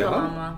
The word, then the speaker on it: Kalama: Kalama. – Mm-hmm. Oh, okay. Yeah, so it was mispronounced after generations. – So it's Kalama. – They pronounce it as Kalama, but it's spelled K-A-L-A-M-A.